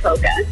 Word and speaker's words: polka: Hey, so do you pronounce the L in polka? – Oh, heck no.